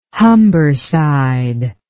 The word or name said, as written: Humberside